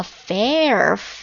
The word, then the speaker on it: fare: 好，今天要分享第三个单词呢，叫做 fair。什么呢？是公平那个单词吗？ No，读音一样，但是写法不一样。 Fair，F-A-R-E，它是一个名词，我相信很多同学应该都知道这个单词的用法啊。